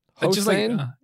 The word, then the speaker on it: Hossain: Hossain? Just like Barack Hussein, your last president. Yeah, pretty similar.